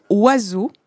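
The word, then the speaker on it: oiseaux: On prononce aucune lettre de oiseau. On prononce pas le O, on prononce pas le I, on prononce pas le S, puisqu'on c'est un Z. On prononce pas le E, on prononce pas le A, on prononce pas le U et on prononce pas le X.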